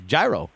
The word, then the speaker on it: gyro: So Chris is the one who taught me how to say. I never even knew the right way to say this word. Gyro.